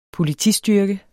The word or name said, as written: Politistyrke